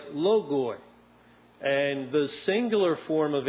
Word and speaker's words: logoi: Now that Greek word words is logoi. We would spell it L-O-G-O-I with an O-I ending.